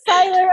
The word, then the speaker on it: Seiler: Seiler on the episode today.